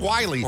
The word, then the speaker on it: Wyly: W-Y-L-Y? Is that like Wiley? – W-Y-L-Y? – Is that Wiley? – Wiley. – It's Wiley. – Or Willie, a bad spelling of Willie? – Let's call him Wiley James Weeks.